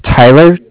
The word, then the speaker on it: Tyler: My name is Tyler, T-Y-L-E-R, and I'm calling with Cardiff. It's spelled C-A-R-D-I-F-F, as in Frank Frank.